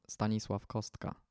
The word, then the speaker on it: Stanisław Kostka: So my Jesuit saint, which was very helpful at the moment when I was discerning my vocation, is Saint Stanisław Kostka, or as we pronounce it in Poland, Stanisław Kostka.